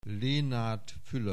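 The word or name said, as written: Fülöp Lénárd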